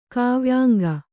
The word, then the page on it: ಕಾವ್ಯಾಂಗ: kāvyāŋga